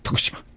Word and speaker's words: Fukushima: I learned how to say it as the Japanese do, or the way I heard the Japanese, some Japanese people saying, people speaking Japanese. Fukushima. We say Fukushima.